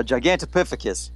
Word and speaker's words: Gigantopithecus: Gigantopithecus.